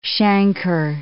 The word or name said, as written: chancre